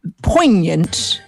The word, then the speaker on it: poignant: May I just say, vis-a-vis jealousy, one poignant, with a silent G, bit of advice.